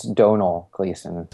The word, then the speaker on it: Domhnall Gleeson: Also, most people don't know, but how do you say his name? Domhnall Gleeson? – I think it's Donal Gleeson.